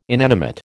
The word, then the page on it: inanimate: American English